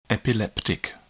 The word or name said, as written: epileptic